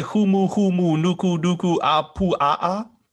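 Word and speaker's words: humu humu nuku nuku apu a'a (humuhumunukunukuāpuaʻa): You're not going to say humu humu nuku nuku apu a'a? – You did it perfectly.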